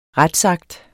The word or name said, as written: retsakt